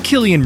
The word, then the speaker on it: Cillian: Dosyć nietypowa wymowa tego słowa. Ja myślałam kiedyś, że to Cillian, a jednak przez K.